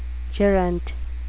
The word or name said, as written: gerent